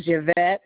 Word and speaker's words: Yvette: Yes, the first name is Yvette, Y-V-E-T-T-E. Last name is McEachin. – Can you spell that last name, ma'am? – M-C-E-A-C-H-I-N.